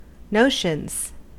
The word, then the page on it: notions: American English